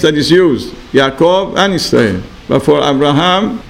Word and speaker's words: Yisrael: Rabbi Shalom gave a new birth certificate to Yaakov and Alam Shalom, and the name was Israel. It's not Israel, it's Yisrael. And also when you say Shema Yisrael, not Israel, you have to make sure you say it the Yud pronunciation, not Aleph.